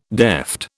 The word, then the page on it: deft: British English